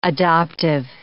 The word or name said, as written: adoptive